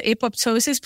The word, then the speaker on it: apoptosis: So coming back to this, you talked about, can you pronounce it again? Is it apoptosis? – Apoptosis.